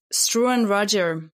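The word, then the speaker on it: Struan Rodger: Struan Roger.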